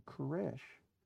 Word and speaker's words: Koresh: And so Vernon must change his name to Koresh, because that is how the name Cyrus is pronounced in Hebrew.